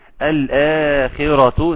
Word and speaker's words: الآخرة: الآخرة، ناخد بالنا من المد بتاع المد اللي هنا في الآخرة، مد مد بدل يمد بمقدار حركتين عند حفص.